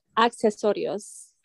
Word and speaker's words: accesorios: Yeah, you basically said it. It's accesorios. – Accesorios. – Yeah, but you can just say accessories because it's basically the same thing.